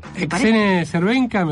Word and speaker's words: Exene Cervenka: Vamos a escuchar a, no sé si esto se pronuncia bien así, Exene Cervenka. – Exene Cervenka, me parece que perfectamente pronunciado.